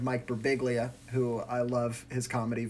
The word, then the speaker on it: Birbiglia: It's Italian, and it's pronounced Birbiglia.